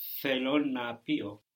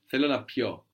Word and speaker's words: θέλω να πιω: Θέλω να πιω. – Good. And the accent's on the end. Θέλω να πιω. The accent falls on the end here when we change it from pino to pio.